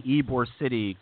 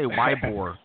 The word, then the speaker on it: Ybor City: That is the studio audience mocking me for finally saying Ybor City correctly, because I always say Ybor, and I'm brown. I should know better than to pronounce the Y in Spanish.